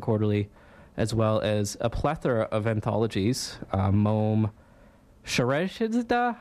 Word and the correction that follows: Scheherazade: how do you pronounce that? – Scheherazade.